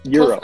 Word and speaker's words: gyro: I mean, who doesn't want a good, gyro, right? Wait, is that not how you say it? – Euro.